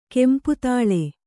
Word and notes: ಕೆಂಪುತೈಲಿ: kempu taili

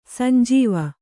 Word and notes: ಸಂಜೀವ: sanjīva

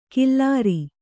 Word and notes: ಕಿಲ್ಲಾರಿ: Kannada; killāri

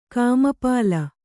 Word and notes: ಕಾಮಪಾಳ: kāmapāḷa

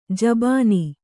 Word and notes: ಜಬಾನಿ: jabāni

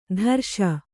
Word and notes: ತರ್ಷ: tarṣa

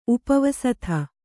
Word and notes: ಉಪಾವಸ್ತೆ: upāvaste

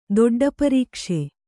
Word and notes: ದೊಡ್ಡಪರೀಕ್ಷೆ: Kannada

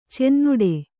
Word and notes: ಚುನ್ನಾಟ: Kannada

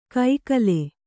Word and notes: ಕೈಕಲೆ: kannada; kaikale

